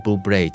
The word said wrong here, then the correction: Bublé: 叫了很多年，然后呢，最近我就查询了一下，发现读音应该是 Bublé，所以是 Michael Bublé。